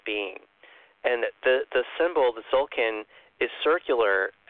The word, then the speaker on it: Tzolkin: I've got to be careful that I don't pronounce it wrong, but I think you say it Zolkin, and I'll spell it for you, T-Z-O-L-K-I-N.